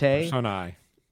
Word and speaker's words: Personae: Personae. A-E is pronounced I in Latin.